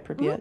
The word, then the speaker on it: Pripyat: Pripyat, mm-hmm.